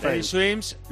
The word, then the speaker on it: Teddy Swims: Teddy Swing, algo, bueno, una cosa así más o menos. Y Fritz Teddy Swims, Lose Control.